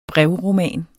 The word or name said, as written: brevroman